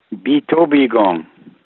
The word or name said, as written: Biitoobiigong